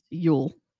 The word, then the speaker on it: Jul: And yes, it is spelled J-U-L and pronounced Yule.